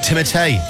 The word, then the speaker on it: Timothée: Or as you are meant to say, Timothée. Do you realise that? That's how you pronounce it, Timothée Chalamet.